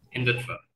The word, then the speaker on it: Hindutva: Because you talk in your piece about how there's a lot of Indian media that, again, is coming out in this pro-Hindu, is it pronounced Hindutva? Hindutva, yeah.